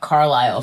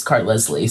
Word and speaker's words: Carlisle: I also thought Carlisle from Twilight was Cart Leslie, so I just had poor pronunciation as a child.